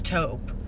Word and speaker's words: taupe: I love taupe. – Is it taupe? Is that how it's said, pronounced? – Taupe. – Yes!